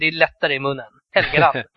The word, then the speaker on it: Helgeland: Och det är ett hårt kort G. Det är, alltså det är typ så här Brian Helgeland.